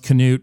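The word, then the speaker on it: Knute: Yeah, technically it should be pronounced Canute. But everyone's dropped the K.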